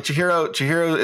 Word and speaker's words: Chihiro: And did I say his name right? – Yeah, Chihiro. Okay. Yep, you got it.